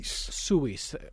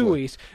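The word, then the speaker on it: Suesse: Suisse.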